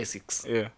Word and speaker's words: ASICS: Someone like KO, ASICS. – Yeah. – Is it ASICS? Or? – I don't know how to pronounce that show up to date. – Yeah, ASICS.